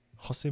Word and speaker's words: José: Nee, aber gerade bei portugiesischen Namen hört man ja auch äh, ganz oft, ja, jo, äh, nicht Jose, sondern José, José Mourinho. – Aber es ist José Mourinho.